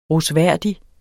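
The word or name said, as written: rosværdig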